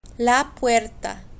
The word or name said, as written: la puerta